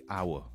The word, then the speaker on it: hour: On regarde les coups, les nous dit bon mot qui est un petit silent letter. Tout au moins, c'est pas hour. Les dit hour.